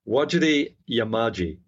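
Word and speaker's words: Wajarri Yamaji: I did mispronounce that, actually, because there's when you see it written, what you pronounce is slightly different. It is Wajarri Yamadi. That's the correct pronunciation.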